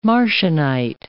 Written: Marcionite

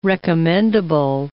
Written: recommendable

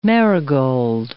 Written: marigold

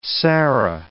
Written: sarah

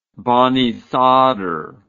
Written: Banisadr

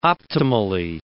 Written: optimally

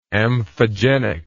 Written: amphogenic

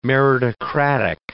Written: meritocratic

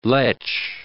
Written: lech

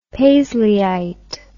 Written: paisleyite

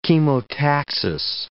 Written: chemotaxis

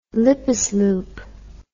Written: lippesloop

